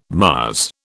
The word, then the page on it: Mars: British English